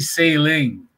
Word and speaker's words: Salling: Bon, c'est arrivé, euh, c'est arrivé à la softball, là, Jen Saling euh, la personne qui, qui, s'occupait de l'équipe Canada, son nom s'écrit S-A-L-L-I-N-G. Là, lui, il me dit sailing.